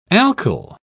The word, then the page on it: alkyl: British English